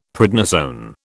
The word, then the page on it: prednisone: British English